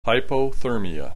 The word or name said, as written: hypothermia